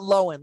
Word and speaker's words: Lohan: They're like, she literally says it Lohan, like L-O-W-E-N.